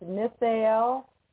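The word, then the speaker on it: Nithael: So this is, okay, so I'm going to spell it out for you because I think it's pronounced Nithail. It's N-I-T, like in Tom, H-A-E-L. Nithail, Nithail.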